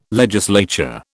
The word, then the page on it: legislature: British English